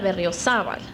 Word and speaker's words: Berriozabal: So I'll get to pronounce it because it still was not the way it should be pronounced. My name is Maria Antonieta Berriozabal. The accent is different. And it's a very beautiful name.